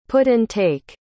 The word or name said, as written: put-and-take